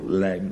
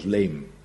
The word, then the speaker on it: lijm: Als je zangles krijgt, dan leer je ook dat je het woord lijm nooit zo moet zingen, want het is geen gehoor, lijm, dat kan helemaal niet. Dat klinkt niet, dus dat spreek je uit als leem.